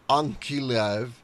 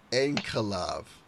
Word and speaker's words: Ankalaev: In the next matchup, in the light heavyweight division, Ion Kuntelaba, 15-4-1, no contest, is taking on Magomed Ankalaev, 12-1, I just butchered his last name, Ankalaev.